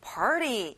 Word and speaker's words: party: t 발음은 잘안 나요. 우리 한번 굴려서 말해볼까요? Party.